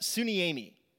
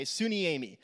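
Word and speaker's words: suniemi (συνίημι): It comes from a Greek word that's pronounced suniemi, okay, suniemi.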